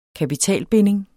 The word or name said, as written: kapitalbinding